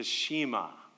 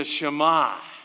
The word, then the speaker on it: Shema: Every good Jewish home would recite the Shema. That's how you say it if you're from Chicago. The Shema.